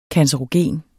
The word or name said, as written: cancerogen